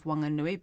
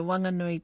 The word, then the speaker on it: Whanganui: Although adding the letter H won't change the pronunciation of Whanganui because it is silent, iwi believe that the proper name should be reinstated.